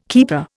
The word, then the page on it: keeper: Indian